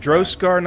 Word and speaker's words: Droskar: You would know that, like, the legend is that the Haringar people have these innate abilities because of their faith to Droskar. – Is it Droskar, not Driscari? Am I saying it wrong? – Yeah, Droskar. Yeah, if you are a worshiper, you are a Droskari.